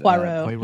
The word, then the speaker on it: Poirot: Poirot. Of, Poirot.